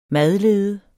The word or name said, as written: madlede